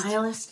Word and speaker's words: nihilist: They're like, Spider Jerusalem is a, help me out, I'm going to mispronounce this word, even though it's a word I really like. Nihilist? – Nihilist? – Nihilist, thank you.